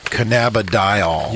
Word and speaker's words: cannabidiol: That is cannabidiol.